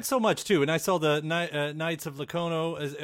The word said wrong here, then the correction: Lono: Am I saying that right? The comic that's on the way, too. I mean, Knights of Lono. – Lono, yeah. That's right.